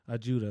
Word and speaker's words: Ajudha: And I'm talking about a lady named Poppy Ajua. – Mm. – Ajudha, excuse me. – How you spell that? – It is P O P P Y A J U D H A.